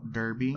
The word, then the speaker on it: Derby: But the first thing, Frank Lampard and Jody Morris, of course, both Chelsea legends, on the brink of managing Derby County. What are your thoughts? – I think you're going to get slaughtered for your pronunciation of Derby County. First of all, Derby.